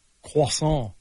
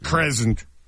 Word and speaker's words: croissant: K-R-C-R-O-I-S-S-A-N-T. – Croissant. – Croissant. Yeah, I go, croissant? – Not crescent.